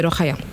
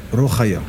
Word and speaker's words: Rokhaya: En fait, euh, la vraie prononciation, c'est Rokhaya. – Rokhaya.